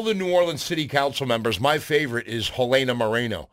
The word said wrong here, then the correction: Helena Moreno: Hold on. I should say that properly. I shouldn't say that like a gringo. Helena Moreno.